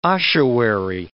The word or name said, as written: ossuary